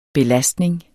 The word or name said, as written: belastning